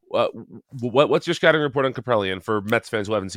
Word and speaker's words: Kaprielian: The biggest discrepancy right now in terms of performance is the Friday night matchup, which is Senga versus James Kaprielian.